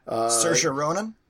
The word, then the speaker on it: Saoirse Ronan: Saoirse Ronan? – Yes.